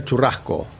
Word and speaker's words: churrasco: Tuna churrasco.